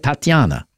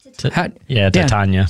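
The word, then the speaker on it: Titania: Am I saying that right? Tatiana. – Tatiana. – Tatiana. See, I wasn't saying it right.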